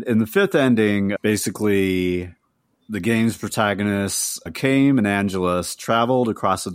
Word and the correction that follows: Kaim: Oh, quick note, his name is pronounced Kaim. – Oh, Kaim.